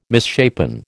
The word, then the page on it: misshapen: American English